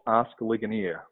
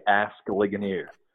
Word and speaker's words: Ligonier: January, we launched Ask Ligonier, or as Americans say, Ask Ligonier.